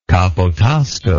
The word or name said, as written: capotasto